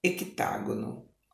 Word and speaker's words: hectágono: Aqui é H-E-C-T-A-G-O-N-O. Esse C é mudo hecta, hectágono, ok?